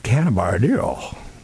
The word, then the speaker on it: cannabidiol: And I don't know anything about cannabidiol. I must have to be high to say it correctly, right? Cannabidiol.